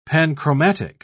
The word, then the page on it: panchromatic: British English